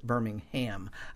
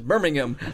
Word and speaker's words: Birmingham: There's one of the oldest around, and then when I went up and spoke in Birmingham, and you have to pronounce that properly, I mispronounced it, Birmingham, a few times. – Oh, oh, they get a good laugh out of that. We're not in Alabama. It's Birmingham.